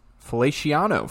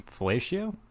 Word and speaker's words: Feliciano: The Bills are activating their guard, Feliciano, from injured reserve. – Felatio? – It's Feliciano.